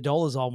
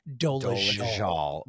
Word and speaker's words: Dolezal: So it's going to be the year of the Dolezal once again. Oh, excuse me. I keep forgetting it's pronounced Dolezal.